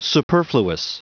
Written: superfluous

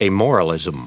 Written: amoralism

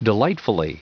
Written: delightfully

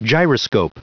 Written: gyroscope

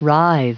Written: writhe

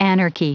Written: anarchy

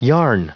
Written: yarn

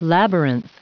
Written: labyrinth

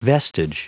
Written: vestige

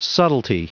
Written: subtlety

